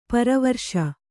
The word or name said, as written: ಪರಿವರಿಸು